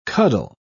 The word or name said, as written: cuddle